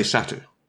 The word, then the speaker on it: satyr: How do you say that? – I say satyr. – Oh, we'll go with satyr then.